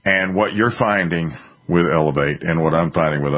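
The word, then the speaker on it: LAV8: Letter A, letter V, 8. Pronounced Elevate.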